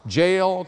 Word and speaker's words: Jael: And then he goes to sleep in her tent, and while he's asleep, the Bible says that Jael, the wife of Heber, took a nail. It rhymes. Jael took a nail.